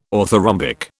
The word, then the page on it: orthorhombic: British English